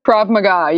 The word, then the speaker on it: Krav Maga: Krav Maga? Krav Maga, yeah.